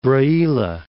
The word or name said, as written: Braila